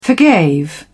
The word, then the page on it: forgave: British English